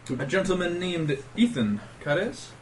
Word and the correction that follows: Karras: Am I saying that right? Karras? – Ethan Karras.